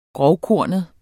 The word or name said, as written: grovkornet